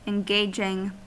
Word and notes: engaging: American English

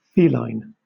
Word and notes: feline: British English

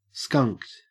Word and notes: skunked: Australian English

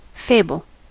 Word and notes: fable: American English

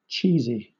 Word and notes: cheesy: British English

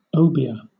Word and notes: obeah: British English

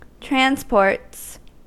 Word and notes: transports: American English

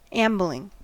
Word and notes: ambling: American English

